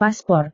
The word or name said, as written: paspor